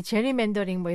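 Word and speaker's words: gerrymandering: 네, 이 하원 의원 선거구라면 이게 이른바 이 게리맨더링하고 관련이 있는 거죠. – 네, 맞습니다. 미국인들은 보통 이 제리맨더링 뭐 이렇게 발음하곤 하는데요.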